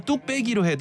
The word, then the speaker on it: 뚝배기: 그러니까 발음은 뚝 빼기로 해도 표기할 때는 뚝 배기인 거예요.